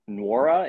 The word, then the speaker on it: Nwora: But I also, Brian Anderson was calling him Nwora and even said that Billy Kennedy got it wrong when he called him Wara at the score sale. I was like, Brian Anderson, come on, read the pronunciation guide, man.